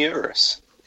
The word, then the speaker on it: Urus: And the Lamborghini Urus, if I'm pronouncing that correctly.